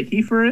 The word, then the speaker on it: heifer: Can you even imagine what a heifer is?" And I go, it's a cow, and it's pronounced heifer.